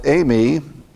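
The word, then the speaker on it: eimi (εἰμί): The word eimi, in this Greek word pronounced eimi, because you have a diphthong, or eimi, or like way, refers to existence.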